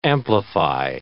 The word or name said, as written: amplify